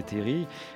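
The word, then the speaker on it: terrils: c'est-à-dire effectivement la plaine sur cette chaîne des terris. On ne prononce pas le L, hein, nous, parisiens, parfois on dit les terris, mais enfin, on ne prononce pas le L là-bas.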